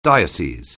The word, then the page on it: diocese: anglais